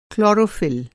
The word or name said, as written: क्लोरोफिल